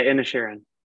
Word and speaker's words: Inisherin: Am I saying that right? – Yeah, Inisherin.